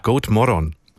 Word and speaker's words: God morgon: God morgon. – God Good morgon, muss ich sagen. Okay, Betonung auf der letzten Silbe.